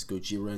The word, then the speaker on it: Gojira: And a lot of people confuse them for Godzilla because the whole thing of Godzilla is pronounced as Gojira in Japan.